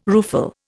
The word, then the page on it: rueful: Indian